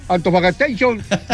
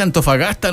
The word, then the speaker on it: Antofagasta: My name is I'm from Chilean Antofagastation de la RD número 1, Black Combata. – Oiga, no se dice Antofagastation, se dice Antofagasta nomás, el nombre como es nomás.